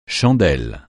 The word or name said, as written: chandelle